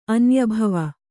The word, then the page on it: ಅನ್ಯಭವ: Kannada